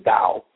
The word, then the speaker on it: Tao: And the Chinese word for path is Tao. So I thought Tao of Twitter was kind of a clever title. And that actually the Chinese, official Chinese pronunciation is Dao.